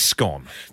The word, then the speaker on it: scone: I know how scone is pronounced, and it's scone.